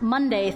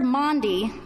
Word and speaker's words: Maundy: Words like Passover or Pentecost or Maundy, as in Maundy Thursday. Often pronounced Monday Thursday by the younger ones in our congregation, this word and the meaning behind it have always fascinated me.